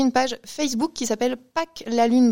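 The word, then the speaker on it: PaQ'la Lune: Pâques la Lune. Donc, l'association Pâques, ça s'écrit P-A-Q. Et là, il faut faire attention parce que donc, il y a un P majuscule, un A minuscule, un Q majuscule, apostrophe, la, L-A, et plus loin, lune, L avec L majuscule, U-N-E, et plus loin, Bretagne avec un B majuscule.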